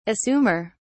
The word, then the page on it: assumer: British English